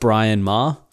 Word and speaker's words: Bryn Mawr: It's spelled Bryn Mawr, like Brynmawr. And like they had like this awesome radio station in the 70s, but it's pronounced Brian Maher.